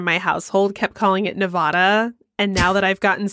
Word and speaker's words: Nevada: Unless it's like Nevada, where they get really upset if you say it wrong. – Yeah. – I fixed myself on that one really quick. I used to be a Nevada person, but it's Nevada.